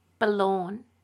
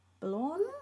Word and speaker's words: Bullaun: Balorn? – Yeah, it's spelled B-U-L-L-A-U-N. Balorn? – Mm. – I think that's how you pronounce it.